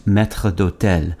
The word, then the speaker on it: maître d'hôtel: In French, it's pronounced maître d'hôtel.